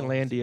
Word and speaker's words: Landi: You can find me using my first name, Eric, spelled with a K, Landy, L-A-N-D-I, on all the social media garbage.